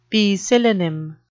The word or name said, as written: poecilonym